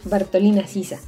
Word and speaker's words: Bartolina Sisa: Durante la presentación lo dije mal, pero es Bartolina Sisa,